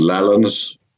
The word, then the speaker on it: Lallans: the, the, the old pronunciation and spelling of lowlands was lowlands, lowlands. Uh-huh. Actually, the Scottish language history magazine is called Lallans, L A L L A N S.